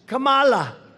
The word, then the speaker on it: Kamala: No, my name's Kamala. Like comma. I remember that. Like a comma.